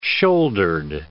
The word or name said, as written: shouldered